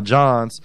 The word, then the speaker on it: jawn: So I went out there with as my boy CPK said, would say, I went out there with one of my Johns from back in the day, J-A-W-N, John or whatever. That's how they say it.